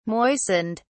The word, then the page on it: moistened: British English